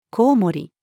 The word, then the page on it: 蝙蝠: female